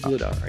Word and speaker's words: Vladar: No, it's Vladar. I knew that. – I, I, okay. I watched him play some games with the Bruins with Swayman. How about that combo? Swayman and Vladar.